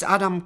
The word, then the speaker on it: Adam: My name is Adam Kos. – How do you spell that, please? – It's A-D-A-M-K-O-S.